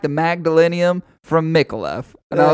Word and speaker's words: Micallef: I assume it's pronounced Micallef. – Micallef. – Right, that is what, to me, that is what I would think is how you pronounce it.